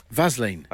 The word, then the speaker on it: Vaseline: Because that is how it's pronounced. – One more time? – Vaseline.